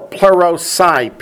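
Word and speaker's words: plerosai (πληρῶσαι): This word fulfill, plerosai, P-L-E-R-O-S-A-I, plerosai, is the same word. Plerosai, P-L-E-R-O-S-A-I, plerosai. From the root word plerao, P-L-E-R-O-O.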